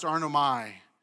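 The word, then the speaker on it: arneomai: This Greek word of deny comes from it actually is pronounced arnomai, which means literally to disown.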